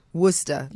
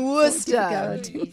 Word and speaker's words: Worcester: Worcester. – Yes. – You see, that sauce that none of us can pronounce. – Exactly. – It's in Worcester.